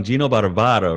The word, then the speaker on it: Gino Barbaro: Annette, I love the way you pronounce my name. It rolls right off the tongue. Gino Barbaro. Very good.